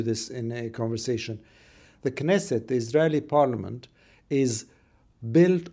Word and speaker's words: Knesset: We've talked Israeli elections, and I've learned a bit about the new members of the Knesset. Have I pronounced that right? – Yeah, absolutely, the Knesset.